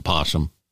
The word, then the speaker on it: possum: I think that's the correct spelling, but the pronunciation here in the South has always been possum.